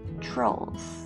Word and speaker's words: Trolls: トロールってちょっとね発音難しいですが Trolls Trolls って言います。